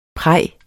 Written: praj

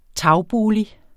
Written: tagbolig

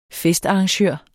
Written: festarrangør

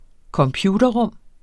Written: computerrum

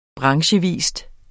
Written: branchevis

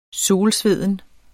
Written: solsveden

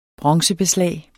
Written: bronzebeslag

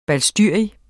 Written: balstyrisk

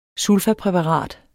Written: sulfapræparat

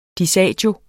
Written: disagio

